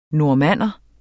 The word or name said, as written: normanner